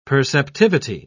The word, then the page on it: perceptivity: British English